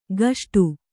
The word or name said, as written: ಗಷ್ಟು